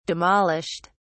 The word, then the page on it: demolished: British English